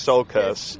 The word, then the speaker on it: Tsiolkas: I always pronounce his name wrong. So correct me if I'm wrong. But uh, Christos. – Sol- Christos Tsiolkas. – Yes.